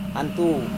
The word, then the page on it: antu: Serewen